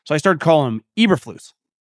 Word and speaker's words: Eberflus: Do you remember when you used to call this guy Uberflus? I used to call him Uberflus. And then it turns out his name is actually pronounced Eberflus.